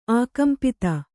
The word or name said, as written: ಆಕಂಪಿತ